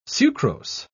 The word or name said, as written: sucrose